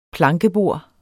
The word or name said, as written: plankebord